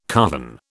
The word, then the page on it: carven: British English